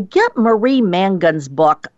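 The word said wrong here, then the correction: Mongan: Yeah, it's Marie Mangan. I'm sorry, I mispronounced her name. It's M-O-N-G-A-N.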